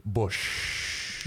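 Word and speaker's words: Busch: And like the beer, by the way, when you pronounce it, you're supposed to say bush.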